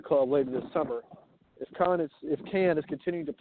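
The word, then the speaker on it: Kann: Oh, it's Alec Khan. Yeah, from Decatur. Yeah, Alec Cann. I've mispronounced it for a long time too.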